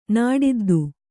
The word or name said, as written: ನಾಡಿದ್ದು